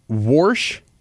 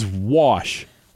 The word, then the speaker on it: wash: Warsh versus wash. – Wash. – We all know the correct pronunciation of that.